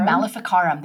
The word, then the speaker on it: Maleficarum: Also, I didn't know how do you pronounce it again? Maleficarum? – Maleficarum, that means witch.